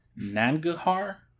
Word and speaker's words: Nangarhar: airstrike in, I'm going to butcher this, Nangarhar province.